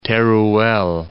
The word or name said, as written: Teruel